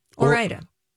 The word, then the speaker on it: Ore-Ida: That, at home, I hope I'm pronouncing this right, Ore-Ida? – Yeah, Ore-Ida.